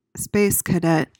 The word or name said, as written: space cadet